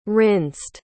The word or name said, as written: rinsed